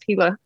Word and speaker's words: Gila: I'm so glad you knew how to pronounce Gila.